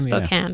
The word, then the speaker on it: Spokane: That's from Holly in 2,000 square feet in Spokane. Is that how you say it? – Spokane, Washington? – Spokane. Yeah. Spokane, Washington, with one husband, 3 teen girls, and a preteen boy.